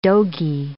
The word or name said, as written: dogie